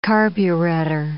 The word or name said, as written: carburetter